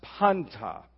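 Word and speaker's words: panta (πάντα): But there's a little word in the Greek language that is, it's pronounced panta. P-A-N-T-A, in this particular form, it's pronounced pas, but it's from the word panta.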